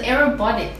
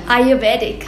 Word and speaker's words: Ayurvedic: But the whole practice of aerobic. – What? No, air. – Ayo. – Verde. – Ayurvedic.